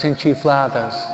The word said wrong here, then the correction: enchilada: Oh, siempre lo digo mal. Enchiflada, enchilada.